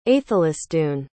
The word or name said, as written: aethelisdun